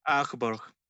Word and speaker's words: Augsburg: Augsburg. Augsburg.